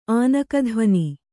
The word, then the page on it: ಆನಕಧ್ವಾನ: ānakadhvāna